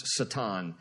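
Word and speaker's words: Satan (שטן): Now, Satan in Hebrew is spelled that way, pronounced Satan, and that word means accuser.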